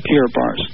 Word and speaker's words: Kiribati: That's spelled K-I-R-B-A-T-I, but pronounced Kiribati.